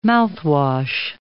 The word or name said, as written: mouthwash